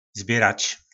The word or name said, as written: sbjyrac